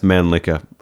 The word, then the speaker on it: Mannlicher: I'm saying that's pronounced Mannlicher. I'm not, definitely going with Mannlicher.